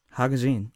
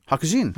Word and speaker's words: hakujin: Hakujin. How you say it? – Hakujin. – Hakujin. – Yeah. – Okay, that's good.